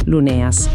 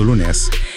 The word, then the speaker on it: Luneas: Cerchiamo di pronunciarlo bene, Luneas. – Esatto, Luneas.